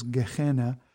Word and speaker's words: Gehenna: Sheol, Abaddon, and the term that's often translated hell, which is Gehenna. – He said those are way better than I did. I don't know if anybody noticed that.